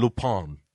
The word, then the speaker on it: Lupin: Se pronuncia la pronunciación Lupin. – Lupin. – Sí, pero en español.